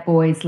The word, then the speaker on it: Boyes: Yeah, boys, like boys and girls.